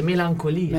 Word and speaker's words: Melancholia: Innanzitutto ci dicono: vi prego, vi prego pronunciatelo correttamente, melancolia. Melancolia, ma che errori.